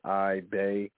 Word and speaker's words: Iba: And it's crazy because they tell – you know, they ask your coach how you spell the last name, so you give them the pronunciation, Iba, and it's still somehow.